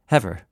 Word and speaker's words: Eber: The word Hebrew in English pronunciation comes from named Ever. You'll see Ever in the Bible. E-B-E-R. Hebrew. Hever. Heveru.